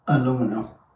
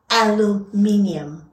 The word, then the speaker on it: aluminium: Aluminum. – Aluminium. We pronounce every letter.